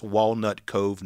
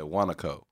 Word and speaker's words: Walnut Cove: I thought you said Wanaco. – That's how it, Wanaco is how I'd pronounce it if I was just using my accent. But, like, we have things like that in Texas, like Whataburger. – Yeah, exactly. It's just, it's the dialect.